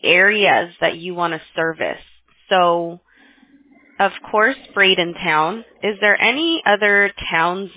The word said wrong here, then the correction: Bradenton: There's Bradenton, just so you know. Don't spell it town. Ton. – Oh, yeah, Bradenton.